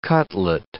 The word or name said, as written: cutlet